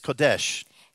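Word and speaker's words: Kodesh (קודש): The word for holy in Hebrew is kodesh. – Das Wort heilig im Hebräischen ist Pedesh? – Kodesh. – Kodesh.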